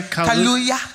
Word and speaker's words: Kaluuya: Kaluuya. – Kaluuya.